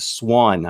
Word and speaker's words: Swann: Swann.